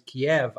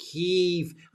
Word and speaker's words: Kyiv: That's because we must never pronounce it as Kiev, as in chicken. I mean, that's one thing I've learned in the past 2 years. Always call it Kyiv. And the longer you make the E, that shows how much more virtuous you are, you see.